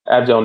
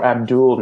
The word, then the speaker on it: Abdel: Abdel Nader, or Abdul Nader is apparently the actual pronunciation of his name.